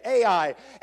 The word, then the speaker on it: Ai: AI. That's exactly how you say it. AI.